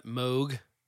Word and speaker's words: Moog: That Moog,